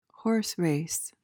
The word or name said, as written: horse race